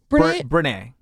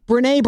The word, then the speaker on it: Brené: Brené. – Brené Brown.